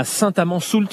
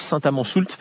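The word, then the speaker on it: Saint-Amans-Soult: L'un d'eux est à quelques kilomètres d'ici, à Saint-Amand-Soult. C'est comme ça qu'on prononce, Saint-Amand-Soult.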